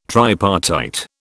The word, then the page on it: tripartite: British English